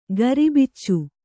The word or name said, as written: ಗರಿಬಿಚ್ಚು